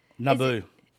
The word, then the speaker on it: Naboo: When they're at N-A-B-O-O. Is that how you how you pronounce that? – Naboo. – Okay, yeah, it's Naboo.